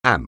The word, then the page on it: amp: anglais